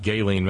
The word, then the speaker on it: Ghislaine: And yes, Darlene, I know it's pronounced Ghislaine Maxwell. LOL. How? I don't know. Ghislaine. And it's Ghislaine. Nah, I'm sorry. I'm a hillbilly. I'm gonna pronounce it like it looks. And the goddess of irony demands no less, and consequently it is Ghislaine Maxwell.